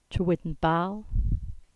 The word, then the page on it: Trewidden Bal: English